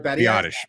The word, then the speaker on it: Biadasz: If Badiaz, that's how you say it, right? – Biadasz. – Biadasz.